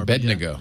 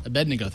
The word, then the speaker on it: Abednego: Abednego. – Yeah. Abednego.